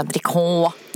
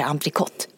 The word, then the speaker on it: entrecôte: Alltså, det heter inte entrecôte, det heter entrecôte. Jag har sagt entrecôte i hela mitt liv. Jag fyller snart 50. Mm. I vetskapen om att det heter entrecôte. Men det uttalas entrecôte.